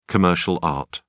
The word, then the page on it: commercial art: British English